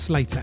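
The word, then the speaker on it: SL8R: And then great drum and bass tune we've just had before this was, I think it's pronounced Slater, SL8 the number R, out of Manchester.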